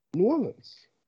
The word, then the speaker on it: New Orleans: The Ravens heading to New Orleans, however you want to say it. Ravens Saints. – Honey, you're a black man. Say it right. – I don't know. – New Orleans.